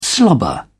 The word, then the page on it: slobber: British English